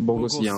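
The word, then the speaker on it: Boghossian: Eh, Bog, ah, hjälp mig med uttalet här. Bog, Boghos, Bog, Bogosian. Bogosian.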